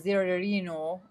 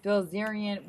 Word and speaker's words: Bilzerian: We are talking about the interesting topic and the question of why is Dan the Zerino? I can't pronounce his name. Sorry. God dang it. Bilzerian.